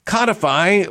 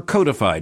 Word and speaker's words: codify: Codify or codify, depending upon which side of the Atlantic you may be on and which pronunciation you may prefer.